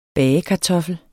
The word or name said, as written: bagekartoffel